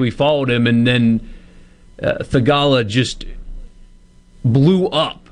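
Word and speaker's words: Theegala: Satith Thagala. Oh my gosh, I butchered his name. Thagala. Satith Thagala is back.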